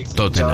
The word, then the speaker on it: Tottenham: Todos los equipos que terminan con ham, uh-huh, H-A-M, bueno, la H es muda. Ejemplo, Tottenham, Birmingham. Creo que la única excepción es West Ham, pero justamente porque se escribe separado.